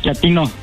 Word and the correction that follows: chatino: No, chatino. – Es como chato, ¿verdad? Chatino. – Sí, asunero, como chato, chatino.